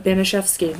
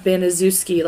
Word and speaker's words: Banaszewski: But Banaszewski, not Banaszewski like it's spelled.